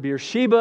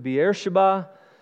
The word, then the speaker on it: Beersheba: And on the way, he stopped in, some pronounce it Beersheba, others pronounce it Beersheba.